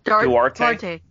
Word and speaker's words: Duarte: How do you say his name? Durat? Durate? – Duarte. – Durate, yeah.